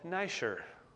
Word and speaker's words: Niger: Simon, called Niger. That's how you pronounce that. Make sure you pronounce that right.